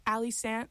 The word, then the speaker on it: Allie Sandt: Yeah, if you look up Ali Sant, A-L-L-I-E-S-A-N-D-T, it rhymes even better.